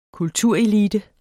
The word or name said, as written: kulturelite